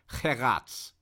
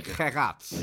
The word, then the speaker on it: Geraerts: We're um, thinking about uh, how to pronounce the name of the new coach correctly, we say Karel Geraerts. Is that correct? – Yes, ah, correct. Yes. Okay, Geraerts. Yes.